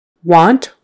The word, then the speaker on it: want: T-H-I-N-G, thing, E-E. Want, want, spelled with an A, say it like a short O.